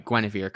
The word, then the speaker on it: Guinevere: And then, like, I'm I know I'm saying it right, Guinevere, because that's how they say it in Once Upon a Time.